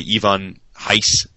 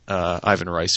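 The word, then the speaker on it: Ivan Reis: Ivan Heiss, Ivan Rice, for those who don't want to say his name the proper way.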